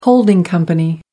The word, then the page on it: holding company: American English